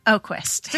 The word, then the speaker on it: Oquist: Did I say that right? – Yeah, Oquist. – Your last name is always so tricky. – You know, it's more phonetic than people think it is. – I don't even know what phonetic means, but I'm going to roll with that. – It sounds like the way it's spelled.